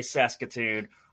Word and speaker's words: Saskatoon: so scared to say Saskatoon, or that's actually how you say it.